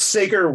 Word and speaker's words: Sager: It's Sager Weinstein.